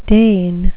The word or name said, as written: deign